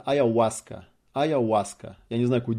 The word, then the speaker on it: Аяуаска: Буква H, Очо, или как она там, Ачо. Она не читается в испанском языке, ни на каком языке. Называется Аяуаска. А не Аяхуаска.